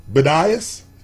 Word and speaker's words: Bedias: Okay, in Bedias, Texas. – Ooh, you can spell that. Spell that. – B-E-D-I-A-S.